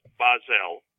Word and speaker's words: Bahzell: Well, it's Bahzell.